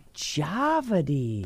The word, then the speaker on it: Djawadi: Djawadi.